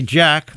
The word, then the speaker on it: JATK: I want to talk about a new album out, new LP by Jack. That's spelled J-A-T-K.